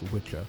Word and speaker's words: wicca: So that's how the Old English word got revived and repronounced, because in the Old English it should be pronounced Witcha, but that's just me pulling hairs.